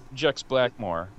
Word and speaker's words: Jex Blackmore: I saw him on one of the local TV shows with, I don't want to get her name wrong, Jex Blackstone? Was Jex Blackmore.